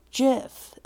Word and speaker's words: GIF: I would just pronounce it the way I would pronounce a jar of peanut butter. Jif.